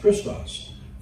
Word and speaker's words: Christos: But the fact is, Christos is the word in Greek. That's how it's pronounced, Christos, from when we get the word Christ.